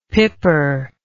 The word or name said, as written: pipper